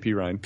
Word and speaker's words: Perine: It's Perrine, right? – Samaje Perine.